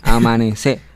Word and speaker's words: amanecé: Recuerden que es Amanecer sin R, Amanecer. con acento en la E.